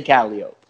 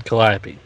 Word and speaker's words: Calliope: But if you ask anybody with the Superfly connections what that word is and how you say it, they'd say Calliope. – Well, we asked the guys at C3, and they said Calliope.